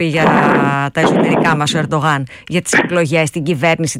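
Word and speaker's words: Erdoğan: Μέχρι να έρθει ο κ. Ερντογάν, που είναι η σωστή προφορά με τα τουρκικά της Ανατολίας,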